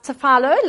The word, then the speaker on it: tefillah: But in the Old Testament specifically, the Bible uses the word tefillah, pronounced tefillah, like phyllo pastry.